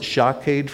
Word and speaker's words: shaked: You pronounce it shaked for almond, shoked for watchover.